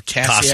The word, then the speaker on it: Kasia: Keisha Shelton. – Spell that. Yeah, fine. Spell it. – K-A-S-I-A. Or Cassia. – Cassia, yeah.